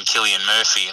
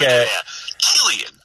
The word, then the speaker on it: Cillian: I remember somebody referring to him and saying Cillian Murphy, and I was like, I'm sorry, who? – Yeah. – She's like, yeah, that's how you pronounce it.